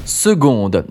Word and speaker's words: seconde: Seconde. Déjà un petit point sur l'orthographe du mot. Seconde s'écrit S-E-C-O-N-D-E. Oui, oui, il y a bien un C qui se prononce G.